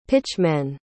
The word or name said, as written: pitchmen